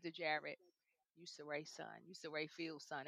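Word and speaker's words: Cirie: How do you pronounce the name? I don't want to say it wrong. Saray. – Saray, yes.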